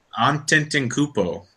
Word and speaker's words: Antetokounmpo: It's Giannis Antetokounmpo, something like that. – Antetokounmpo.